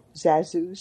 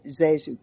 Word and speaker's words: ZaSu: Z-A-S-U. Zazu, some people called it. Some called it Zazu Pitts.